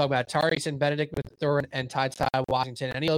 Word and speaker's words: Mathurin: I actually don't know how to say his last name. Is that right? Mathurin. – I've heard Mathurin most broadcasts.